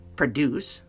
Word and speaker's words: produce: Then finally, we have P-R-O-D-U-C-E. Produce, the verb. She produces a lot of content. Or the farmer produces a lot of vegetables or fruit. Then we have produce. Now that it's a long O sound, produce.